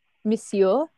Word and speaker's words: Monsieur: Well, I guess it's Monsieur. Is that how you say it? Monsieur Lancelin, that's the father, went to his office. – Monsieur? – Yeah, that's it.